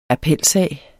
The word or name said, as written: appelsag